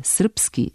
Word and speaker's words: srpski: Prema starom etimološkom, pravilno je bilo srpski, srpkinja, srpstvo sa b, a prema fonološkom pravilno je srpski, srpkinja, srpstvo sa p.